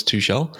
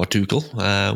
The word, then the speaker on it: Tuchel: So he actually spent some time with Thomas Tuchel, or Tuchel, would depend on which way you want to pronounce it.